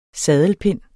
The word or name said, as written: saddelpind